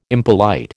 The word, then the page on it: impolite: American English